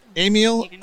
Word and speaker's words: Ameel: Ameel.